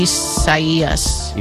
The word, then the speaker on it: Isaias: Well, no, but it's probably the Spanish rendering of it, which in case, if that's the case, then the I is pronounced like an E. – Isaias.